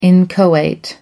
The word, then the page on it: inchoate: American English